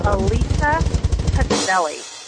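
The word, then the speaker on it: Elisa Pacelli: My name is pronounced, drumroll please, Alisa Pazzelli. Hope this ends the confusion.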